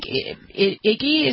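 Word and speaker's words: Ige: If you go to Hawaii, the governor there, David, I think Iggy is how you say his name. I always forget it. It's I-G-E.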